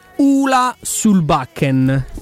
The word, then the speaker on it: Solbakken: salutiamo intanto Paolo Roma che ci dice nota, si pronuncia Ula sul Backen.